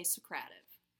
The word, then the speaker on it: Socrative: But we listened to one of the online tutorials sponsored by the Socrative people, and they say Socrative. – We should just send them an email. Okay. – Tomato, tomato, Socrative, Socrative. – Okay. – It rhymes with Socratic, as in a Socratic dialogue.